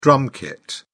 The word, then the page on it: drum kit: British English